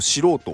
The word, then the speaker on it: 素人: この「す」っていう字に「人」って書くと「素人」って読む